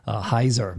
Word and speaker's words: Heiser: All right, so if you listen to Michael Helzer or Hesner, I wish I knew how to say it. Someone told me there's no L. Heiser, Michael Heiser, thank you.